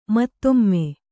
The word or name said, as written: ಮುತ್ತಮ್ಮ